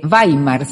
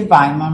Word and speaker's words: Weimar: ¿por qué elegiste Weimar, se pronuncia, no? Sí, se dice dice Weimar, muy bien.